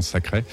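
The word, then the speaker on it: Thackray: Donc, Imagine Sacre, si on fait semblant de le dire à l'anglaise, donc Imagine Sacre. Sacre, ça s'écrit Thacray, qui est une musicienne incroyable.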